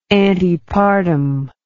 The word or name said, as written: antepartum